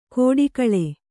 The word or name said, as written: ಖೋಡಿಗಳೆ